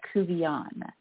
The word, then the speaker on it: Couvillon: Laura Covillon.